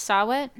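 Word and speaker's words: saw-whet: I don't know how to pronounce Sawit. – Sawit. – Sawhet. Sawit. – Sawit. – Yeah.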